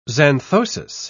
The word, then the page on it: xanthosis: British English